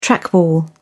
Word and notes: trackball: British English

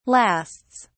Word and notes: lasts: British English

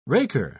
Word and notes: raker: British English

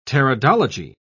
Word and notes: pteridology: British English

